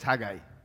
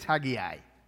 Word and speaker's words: Haggai: I'm Filipino, so it's Haggai. If I'm Caucasian, it's Haggai. And it's easier to pronounce Haggai.